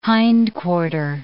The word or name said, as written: hindquarter